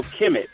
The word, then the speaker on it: Kemet: But one of the places I think we really want to talk about is, is also Kemet, and and that's spelled K M T.